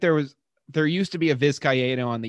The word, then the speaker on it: Vizcaino: Yankees acquire Anthony Rizzo for Alexander Vizcaino. I think I pronounced that right. – I think it's Vizcaino.